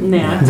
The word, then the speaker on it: gnat: It's gonna, to quote the Gnat, is that, how do you say that word? – Gnat. – The gnat, okay. – No, without the G.